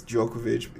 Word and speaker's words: Djokovic: Djokovic, I think it's Djokovic. The D is silent.